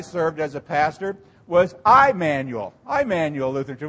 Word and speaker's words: Emmanuel: And so we would not say El Imanu. We would say Emmanuel. Emmanuel.